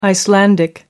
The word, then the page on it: icelandic: American English